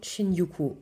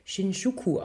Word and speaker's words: Shinjuku: Anne Findeisen, dein Buch heißt die Katzen von Shinjuku. Oder ich habe mich belehren lassen, wahrscheinlich spricht man das Shinjuku aus.